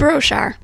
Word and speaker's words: Broshar: How do you say it? – Broshar. – Broshar? – Mm-hmm. – Is that how you say it? – Yeah.